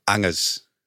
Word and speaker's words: Angers: What's the authentic pronunciation? – Angers. – Sorry? – Angers. The monkey Angers.